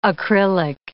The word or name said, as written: acrylic